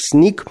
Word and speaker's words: SNCC: И дальше при содействии Бейкер был сформирован студенческий координационный комитет ненасильственных действий (SNCC) обычно произносится просто как СНИК.